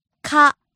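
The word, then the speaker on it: から: Kara. Kara. – Starts high and drops low.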